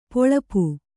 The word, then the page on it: ಪೊಳಪು: poḷapu